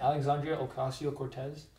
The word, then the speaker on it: Alexandria Ocasio-Cortez: So in efforts of that, AOC, how do you say her full name? Alexandria Ocasio-Cortez? – Yeah. – Something. Said it perfectly.